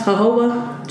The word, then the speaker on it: jojoba: That's jojoba. Jojoba. – You know, we can spell it, you know, like Spanish jojoba. – That oil. – Or African American jojoba.